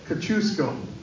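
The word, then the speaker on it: Kosciusko: Kosciuszko.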